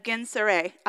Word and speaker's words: Gennesaret: One day, as Jesus was standing by the lake of Gennesaret, I looked that up, y'all, how to pronounce it.